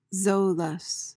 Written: zoilus

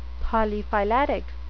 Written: polyphyletic

